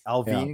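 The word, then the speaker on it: Allvin: It's spelled like Alvin, but it's Alvin because it's like Sedin. He's from Sweden.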